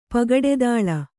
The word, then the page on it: ಪಗಡೆದಾಳ: Kannada